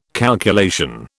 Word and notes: calculation: British English

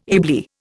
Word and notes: ably: Indian

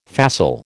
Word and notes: facile: American English

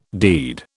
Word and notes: deed: British English

